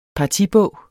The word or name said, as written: partibog